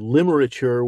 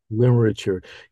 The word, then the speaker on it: Limerature: Among the things that you've been busy doing is this wonderful new book called Limerature 101. Did I pronounce that correctly? – Yeah, that's right. Yeah. – Limerature.